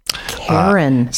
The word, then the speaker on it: Caryn: Karen. – C-A-R-Y-N, it's spelled.